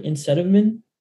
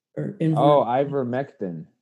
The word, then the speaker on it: ivermectin: like Insetamin? – Ivermectin.